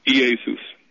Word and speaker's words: Iesus: First of all, the name Jesus, Iesus, is Greek. That's the Greek pronunciation of it.